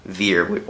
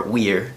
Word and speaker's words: vir: which the word virtue literally comes from the Latin word vir, or weir, depending on your pronunciation, which literally just means man.